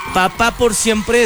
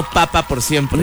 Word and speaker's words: Papá por Siempre: Papá por Siempre es con acento en la A, no Papá por Siempre.